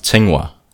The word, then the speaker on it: Tsinghua: Sing you. All right, that was a terrible. that was a terrible How do you say um, it? It's, it's pronounced Tsinghua.